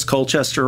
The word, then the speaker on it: Colchester: Did I pronounce Colchester right? – No, you did it right.